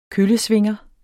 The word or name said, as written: køllesvinger